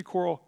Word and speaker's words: Quirrell: How do you say that? It should be Quirrell.